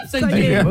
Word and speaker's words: upcycling: Donc ça se prononce upcycling.